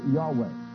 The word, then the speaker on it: Yahweh: Probably pronounced more correctly, Yahweh.